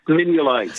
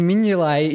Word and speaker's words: Mignolet: It's Mignolet. Simon Mignolet is correct.